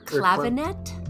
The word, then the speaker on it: clavinet: The Honer clarinet? – Yeah, Honer clavinet? – Clavinet, yeah. It's a V for some stupid fucking reason.